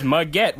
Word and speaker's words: Muggett: His name is Muggett, which rhymes with baguette.